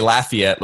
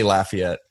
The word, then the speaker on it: Lafayette: I'm a modern country artist from, we say Lafayette, Louisiana here. – Sorry. – We say it, we pretty much understand. We understand that we say it wrong, but we say Lafayette.